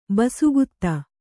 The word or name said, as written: ಬಸುಗುತ್ತ